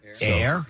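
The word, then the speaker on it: Aire: The Kardashian star certified how to pronounce the name after Instagram accounts captioned video, and they wrote, "Do you think it's pronounced Air or Airy?" And Kylie commented and said, "Air, A-I-R," and added a red heart emoji. So the baby's name is Air.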